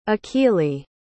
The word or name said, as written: akili